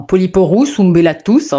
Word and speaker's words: Polyporus umbellatus: Alors effectivement, le polypore en ombelle, son nom latin c'est Polyporus umbellatus. Enfin, Polyporus umbellatus, hein, si vous voulez exactement voir la prononciation.